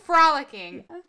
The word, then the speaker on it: frolicking: I'm never going to say this word. – Follicles? – You know the follicles. How do you say the word for me? – Frolicking? – Frolicking. Yeah. You're going to hear the... Frolicking through Scotland.